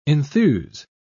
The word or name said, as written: enthuse